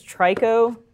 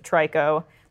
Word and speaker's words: Trico: I believe its name is Trico, or I think that's how it's pronounced, mm-hmm. T-R-I-C-O. – Yeah. Interesting. Yeah. Like Ico, but the third one? – He...